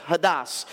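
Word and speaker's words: hodos: So I looked up the word way in the Greek, and it's hadas, H-A-D, or actually it's H-O-D-O-S, hadas, but that's how it's pronounced, hadas.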